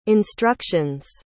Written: instructions

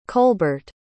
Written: colbert

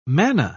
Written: manna

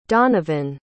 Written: donovan

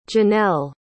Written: jeanelle

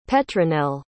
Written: petronel

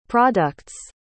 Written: product's

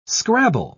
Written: scrabble

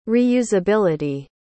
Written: reusability

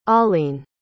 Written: alleen

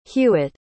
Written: hewitt

